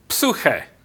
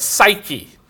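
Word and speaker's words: psuche (ψυχή): And the word soul is the word, if you were to pronounce it in English the way we do, it's psyche. I was taught to pronounce that psuche. But psyche, the mind.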